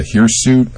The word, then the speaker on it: hirsute: You haven't yet discussed the hirsute. I don't know if I'm pronouncing that correctly. – Yes, you are, yeah.